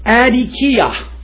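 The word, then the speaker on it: adikia (ἀδικία): The Greek word for unjust is pronounced adikia, and it implies an overall character of life.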